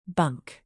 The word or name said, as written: bunk